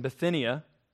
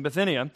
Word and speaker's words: Bithynia: He says here, "Peter, an apostle of Jesus Christ, to those who reside as aliens, scattered throughout Pontus, Galatia, Cappadocia, Asia, and Bithynia." Right, Bob? Did I say that one right? Yeah, right on. Bob helped me out with how to pronounce that. "In Bithynia,